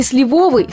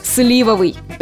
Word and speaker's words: сливовый: Довольно частое в обиходе «сливовый» с ударением на «о» является грубой речевой ошибкой. Авторы некоторых словарей даже отдельно указывают на недопустимость такого варианта. Так что придется смириться и запомнить, что правильно будет «сливовый».